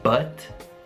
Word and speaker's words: but: But è lo schwa, quindi la vocale è uh, eh, e spesso but però è una, è una parola molto veloce, quindi potete fare tipo but,